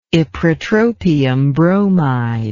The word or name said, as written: ipratropium bromide